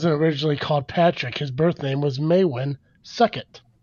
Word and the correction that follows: Succat: I'm pretty sure I spelled, said that wrong, but Suckett sounds better than this. Uh, S U C C A T, Succat.